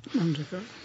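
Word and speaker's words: Manjackal: I właśnie tutaj muszę nawiązać do takiego wydarzenia, które miało miejsce na rekolekcjach, które prowadził ojciec z Indii, ojciec James Maniacal, Nie wiem, czy się tego nie wymawia, Mandżaka. – Mandżaka.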